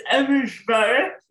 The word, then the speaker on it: Abujbarah: Could you pronounce your last name for me? – It's Abujbarah, but don't worry about it. No one can pronounce it.